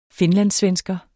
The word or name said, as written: finlandssvensker